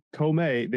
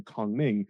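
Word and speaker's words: Kongming: They didn't say Komei, they said Kong Ming. But still, well, I mean, Komei is the Japanese pronunciation.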